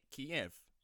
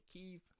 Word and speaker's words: Kyiv: But apparently that is the Russian pronunciation. And the Ukrainian people call it Kyiv, so we shall call it Kyiv from now on.